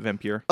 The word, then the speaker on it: Vampyr: is that how we're pronouncing it? – Yeah, I've always called it Vampyr.